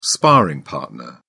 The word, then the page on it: sparring partner: British English